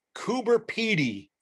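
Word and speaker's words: Coober Pedy: and I ran into a place called Cooper Petey or Petty. – Yes. – And I dropped the little guy. – You pronounce it right the first time. – Okay. – Cooper Petey, well done.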